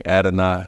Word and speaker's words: Adonai: Adonai, A-D-O-N-A-I. It's pronounced Ad-N-I. If you think about it like that, it's a little easier.